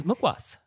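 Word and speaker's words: mukhwas: So this is mukwas.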